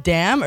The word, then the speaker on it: DAM: Is it Dam or D-A-M? – It's Dam, I believe.